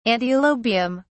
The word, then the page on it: antilobium: British English